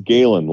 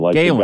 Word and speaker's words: Galen: Illinois lead, and as we look at the replay, that was off of Gatlin Smith. Am I saying that right, Trevor? Gatlin Smith? – I think it's Galen, Galen.